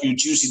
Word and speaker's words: Ujuicy: Uh, how did I how do I pronounce that again pro- properly? Ujusi. That's Juicy. – Juicy. what's Once you touch Ujusi, that's U J U I C Y.